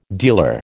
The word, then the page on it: dealer: American English